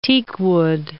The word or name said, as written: teakwood